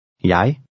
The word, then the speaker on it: jeg: Jeg. Jeg. – Notice that an initial J is pronounced like an English Y, as in yes. The combination EG is usually pronounced I, especially in Danish personal pronouns.